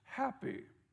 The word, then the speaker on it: Hapi: His name is spelled H-A-P-I, pronounced happy.